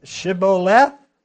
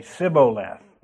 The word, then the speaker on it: shibboleth: It was Shibboleth, and they couldn't pronounce it that way. They could only say Sibboleth. They couldn't get that, I think, H or something in there.